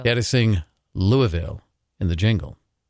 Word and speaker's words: Louisville: It's Louisville. It's not Louisville. It's not Louisville.